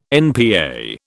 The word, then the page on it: NPA: British English